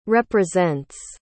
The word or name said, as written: represents